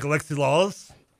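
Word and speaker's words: Alexi Lalas: Can I be like Alexi Lawless? – Yeah. – Is that how I say his name right? – Yeah.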